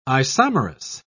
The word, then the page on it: isomerous: British English